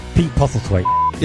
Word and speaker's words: Postlethwaite: Narrated over by Pete Postlethwaite. How do you say that? Postlethwaite? – Pete Postlethwaite.